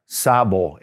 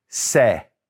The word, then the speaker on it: sé: But it's yo form is not sabo, S-A-B-O. It's this, sé, sé, with S-E with an accent on the E.